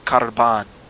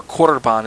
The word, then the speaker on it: korban: The Hebrew word for sacrifice is karban, all right? Or korban is how we actually pronounce it.